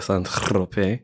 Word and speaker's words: Saint-Tropez: That's how they say it. That's how the locals say it, Saint-Tropez.